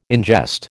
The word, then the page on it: ingest: American English